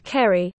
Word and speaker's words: Kerry: By the way, he sometimes now regrets not having included Kerry, hard C, as in K, his middle name, in his writer's name.